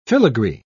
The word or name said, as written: filigree